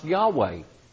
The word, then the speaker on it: Yahweh: And I know it sounds funny, but the name of God revealed in the Bible is the Hebrew word that's pronounced Yahweh.